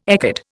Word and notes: ecad: Indian

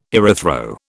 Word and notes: erythro: British English

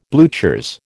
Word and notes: bluchers: American English

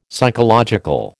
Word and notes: psychological: American English

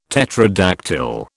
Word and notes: tetradactyl: British English

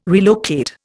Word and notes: relocate: Indian

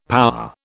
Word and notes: power: British English

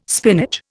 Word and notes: spinach: Indian

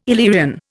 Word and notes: Illyrian: Indian

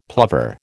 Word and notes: plover: American English

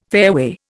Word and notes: fairway: Indian

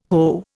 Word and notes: Ho: Indian

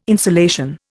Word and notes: insolation: Indian

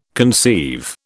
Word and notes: conceive: British English

